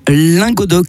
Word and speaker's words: Languedoc: Puisque c'est une radio dite occitane, donc on prononce Radio Languedoc, car quand ça, les lettres A se prononcent O et inversement.